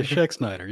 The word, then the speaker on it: Schexnayder: Yeah, Schexnayder. You're right. – Hey, I did it. You got to remember the A is silent. But, oh, kind of.